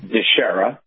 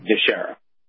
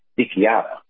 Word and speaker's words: DiChiara: Okay, so in English, okay. Deshera, like Deshera. In Italian, my family, my grandfather on my dad's side and my grandmother on my dad's side, both of them are from Sicily. Mm-hmm. So in Sicily, a CH is a K, and then you roll an R like a D. So my name in Italian is Dichiara. So thankfully, I don't use the Italian pronunciation, or there'd be more trending Twitter stuff, right?